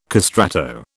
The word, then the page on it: castrato: British English